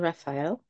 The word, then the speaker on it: Raphael: And today we've got a special guest, Hannah Raphael. Do I pronounce it right? Raphael. Yeah. – Yeah. – Like the Archangel.